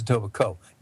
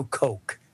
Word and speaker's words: Etobicoke: And it is Etobicoke. No Coke. – It's not Coke. Etobicoke. The K is silent.